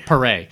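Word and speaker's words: Paré: How do we learn that we would say his name? Perrier? P-A-R-E accent? Perret.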